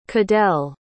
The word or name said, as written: cadel